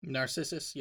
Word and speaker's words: Narcissus: Narcissus. Am I saying it right? – Narcissus.